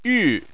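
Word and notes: yu: tone 4

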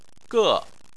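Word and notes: ge4: tone 4

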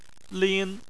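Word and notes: lin: tone 1

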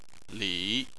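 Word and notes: li: tone 3

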